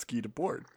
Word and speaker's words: Guy Debord: GUYDEBORD. – Okay. Guy Debois, the Americans. – Guy Debord, no A.